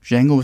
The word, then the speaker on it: Django: So, und ja, seitdem ist es halt so, Django ist ein Silent D.